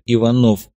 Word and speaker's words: Иванов: Есть иные миры и другие времена, обещал ему большой любитель темноты товарищ Агасфер, он же Иванов, с ударением на втором слоге по-офицерски.